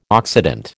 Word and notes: Occident: American English

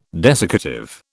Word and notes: desiccative: British English

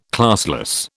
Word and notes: classless: British English

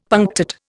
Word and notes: punctate: Indian

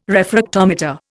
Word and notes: refractometer: Indian

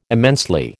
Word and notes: immensely: American English

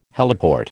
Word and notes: heliport: American English

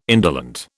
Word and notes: indolent: British English

